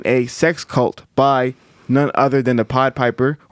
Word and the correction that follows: Pied Piper: or the Pied Piper, whatever he calls himself, R. Kelly. – The Pied Piper. – Pied Piper was right. You said it right.